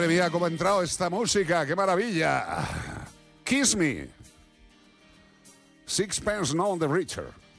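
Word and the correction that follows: Sixpence None the Richer: O algo así. ¿Lo he dicho bien, hija? – Bastante bien. – Bastante bien. – Sí. – A ver, ¿cómo lo dirías tú? Sixpence. A ver, la anglófona. – Sixpence, none the richer.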